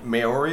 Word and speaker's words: Maori: Maori, is that how you pronounce Maori. – It? – Maori.